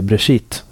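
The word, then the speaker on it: Beresheet: Men vet du vad, jag tror att det korrekta hebreiska uttalet är Brashit.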